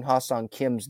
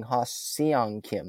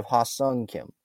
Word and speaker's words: Ha-Seong Kim: Like Melanie Newman, she was pronouncing Ha-Sung Kim's name wrong. She was saying Ha-Seong Kim instead of Ha-Sung Kim.